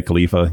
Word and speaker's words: Khalifa: But anyway, this other topic I find funny, but you have Mia Khalifa, you know, did I pronounce it right? – Khalifa.